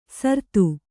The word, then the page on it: ಸರ್ತು: sartu